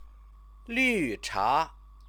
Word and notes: lucha: Chinese

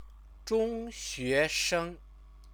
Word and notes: zhongxuesheng: Chinese

